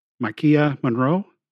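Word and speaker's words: Maika Monroe: It's Maikia Monroe, is how you say her name.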